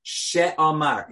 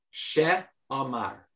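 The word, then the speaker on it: She'amar: B-A-R-U-C-H. Baruch. – All right, Baruch. – Sha'amar. S-H-E. Uh-huh. Apostrophe, if you want. A-M-A-R. Sha'amar.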